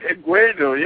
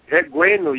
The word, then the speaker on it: Egwuenu: EGWUENU. Yeah, it's the same. – EGWUENU. – You know, it's, yeah. EGWUENU, yeah, that's the right pronunciation.